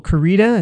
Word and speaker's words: Koryta: It's by Michael Corita. It's spelled K-O-R-Y-T-A.